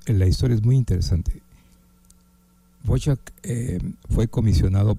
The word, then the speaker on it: Dvořák: Y lo pronuncian de diferentes maneras. Hay, algunos dicen Antonín Dvořák, otros dicen Dvořák. En realidad, la pronunciación en el idioma checoslovaco, que es el idioma original, es Dvořák.